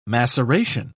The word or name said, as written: maceration